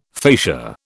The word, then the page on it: facia: British English